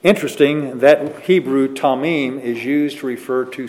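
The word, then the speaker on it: tamim (תמים): The Hebrew for perfect is tamim. We would spell it T-A-M-I-M, tamim, if we'd spell it that way. Pronounced T-A-W-M-E-E-M, tamim.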